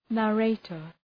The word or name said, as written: narrator